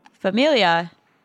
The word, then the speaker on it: famiglia: His wife, Hannah, isn't that Italian for family? – Familia. – But there's a G in it. Oh, I guess the G is silent.